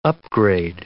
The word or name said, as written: upgrade